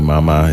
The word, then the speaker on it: 妈妈: Obviously, mama is mom. – Yeah, mom. Mama. Properly in Chinese, the pronunciation is supposed to be mama.So the second tone is actually a light tone.